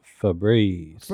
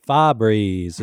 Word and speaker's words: Febreze: No, that was Febreze. – Yeah. Now, Phil, look. – So I witnessed this. – How does day. – Phil pronounce it? – Fabreeze is what Phil calls it.